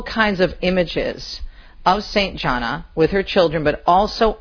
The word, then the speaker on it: Gianna: and it's pronounced Gianna. I learned that when I was over there. It's not Gianna, it's Gianna.